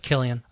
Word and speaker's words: Cillian: It's Killian. – Oh. – It's spelled with a C. – Yeah. – Pronounced with a K.